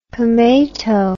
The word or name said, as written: pomato